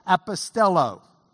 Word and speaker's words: apostello: Apostello. The last O is the omega. It's a long O, so you draw a line over it. Apostello.